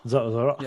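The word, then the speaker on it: Zoreaux: Zorro.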